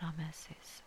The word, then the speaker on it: Rameses: in the land of Rameses or Ramses, depending on where you're from and how it's pronounced, as Pharaoh had ordered.